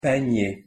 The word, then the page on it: panier: French